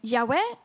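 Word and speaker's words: Jahve: Så när det står På massa ställen i Bibeln så står det att Gud är JHVH och man kan uttala det Jahwe eller Jehova.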